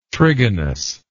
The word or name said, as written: trigonous